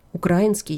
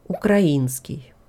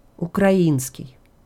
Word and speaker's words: украинский: Те, у кого русский язык родной, часто делают ошибку в ударении. Вот так. Они говорят «украинский язык». Правильное ударение легко запомнить. Украина, украинский. Еще раз. Украина, украинский. Слышите, как похоже?